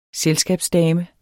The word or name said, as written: selskabsdame